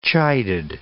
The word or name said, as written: chided